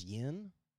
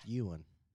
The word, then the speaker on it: yuan: I thought it was yen also. – I did, but and then I watched this clip, and no, it's pronounced. – That's why you confused me there. – It's pronounced yuan.